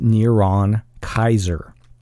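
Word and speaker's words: Neron Kaisar: With the vowel markings, the name would be pronounced Neron Kaiser.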